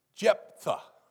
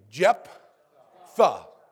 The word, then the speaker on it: Jephthah: But say that with me. Jephthah. Like J-E-P and then T-H-E, like the. Jephthah. That's how you pronounce his name. His name is Jephthah.